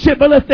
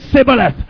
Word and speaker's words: Shibboleth: Did you notice tonight the difference of the pronunciation between Shibboleth and Sibboleth? It was just one consonant. Amen. They couldn't frame the H. All they could say was Sibboleth.